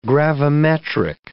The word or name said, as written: gravimetric